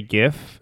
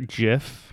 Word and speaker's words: GIF: Side note, it makes me really happy that you say GIF and not JIF. – Oh, I hate that. – Yeah, me too. – The guy who invented it and said that that's how you pronounce it can just go walk off a cliff. Because that's not how that letter works.